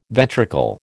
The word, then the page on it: ventricle: American English